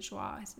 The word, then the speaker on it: Joie: which I mispronounced it before, she pronounces it Joa. It's French, I guess.